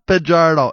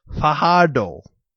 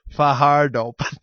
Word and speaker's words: Fajardo: It's not Fajardo, it's Fajardo, okay? That's the actual pronunciation. The J is silent. I heard this from the Rider Radio broadcast. – Okay. – It's Fajardo.